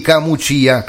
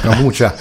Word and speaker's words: Camucia: Camucia, con l'accento sulla I, perché sennò mi picchiano, non Camucia, come direbbe qualcuno che non lo conosce il posto.